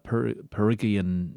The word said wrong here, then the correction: Phrygian: no, oh jeepers, Phrygian, Gordium, associated with Alexander the Great, but it's a metaphor.